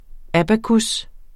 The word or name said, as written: abacus